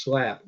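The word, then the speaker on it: Slape: North Carolina Army National Guard Sergeant James Allen's, I guess it's pronounced SLAP with an E, S-L-A-P-E, 23 years old of Morehead City, was killed in Afghanistan yesterday.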